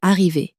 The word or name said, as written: arriver